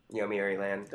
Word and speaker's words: Yomiuri Land: Yomiuri Land. – That was a good pronunciation.